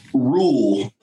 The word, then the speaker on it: Ruhl: Zack, say your last name for me. Ruhl, like obey the rule.